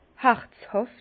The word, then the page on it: herzhàft: Bas-Rhin; Herrlisheim